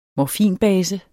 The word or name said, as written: morfinbase